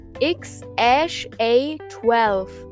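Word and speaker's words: X Æ A-12: Ähm, X Ash A12 wird das ausgesprochen.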